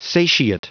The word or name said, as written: satiate